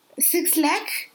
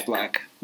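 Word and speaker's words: 6LACK: His name, you're not wrong for pronouncing it Six Lack. – Is it Black or Six Lack? – It's Black. But everyone, the first time they encounter him, says Six Lack. I did it for months until I watched an interview of his where he said, my name is Black.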